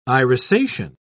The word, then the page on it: irisation: British English